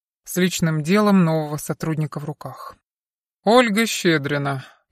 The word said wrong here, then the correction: Щедрина: «Щедрина», — поправила девушка, присаживаясь. «Это русская фамилия, ударение на последнем слоге.